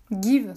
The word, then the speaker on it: Geev: Give, ça s'écrit G-E-E-V.